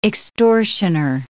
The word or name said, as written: extortioner